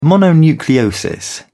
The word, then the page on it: mononucleosis: British English